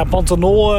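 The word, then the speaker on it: Pantanal: Een, ehm, Pan Pantanol, zeg ik het goed? Ja, Pantanol eh, eh, ja, gebied noemen ze dat.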